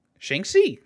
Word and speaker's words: Shang-Chi: That's pronounced Shang-Chi. Shang-Chi.